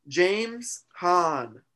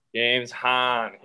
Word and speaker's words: James Hahn: James Hahn. – James Hahn.